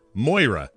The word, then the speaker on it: Moira: Moria. Is that how you say her name? Moira.